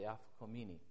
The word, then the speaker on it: Afikomen: Afikomen, not Afikomen. Yeah, Afikomen, okay.